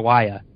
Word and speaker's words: Sawaya: Sawaya, there we go.